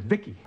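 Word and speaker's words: VICI: V-I-C-I, I pronounce it Vicki.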